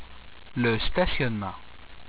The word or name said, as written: le stationnement